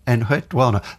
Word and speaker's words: Enheduanna: I should say I can remember how to pronounce her name from Cyndi Lauper, from Girls Just Gotta Have Fun. Just the Wana, Enheduanna. That gives you the right kind of intonation, I feel.